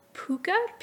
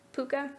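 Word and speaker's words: Puca: And one of them, I read it as Puka, P-U-C-A. I don't know if that's how it's pronounced. In my head, I pronounced it like the dog from Anastasia. Puka? I don't know. – It is how you pronounce it.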